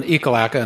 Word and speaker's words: Ekalaka: Ekalaka, and yeah, that's the way it's pronounced, regardless of how it was pronounced on the floor one day, Ekalaka, built a school.